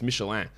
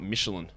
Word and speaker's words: Michelin: Have we settled on the proper pronunciation of Michelin? Yeah, it's Michelin. I don't think there's any debate on that. It's Michelin. It is not Michelin. Because it is a French company that makes tires.